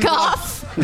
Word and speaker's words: Van Gogh: Van Gogh? – Yeah. – What? – Have some fucking respect for other cultures. That's the proper Dutch pronunciation.